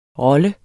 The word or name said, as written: rolle